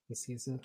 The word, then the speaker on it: Nicieza: And we boil to Fabian Nicieza's, is that how you say his name? Nicieza, Yeah.